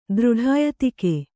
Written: ದೃಢಾಯತಿಕೆ

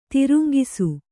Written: ತರಂಗಿಸು

